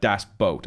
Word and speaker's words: Das Boot: Well, I thought this was interesting because apparently in 3 of the last 4 podcasts, different people have all mentioned Das Boot. And we're saying it wrong. – Right. – It's not Das Boot. – Okay. So, but think of this. boat when you pronounce boot, like das boat, and you're 95% there, which is good enough for me.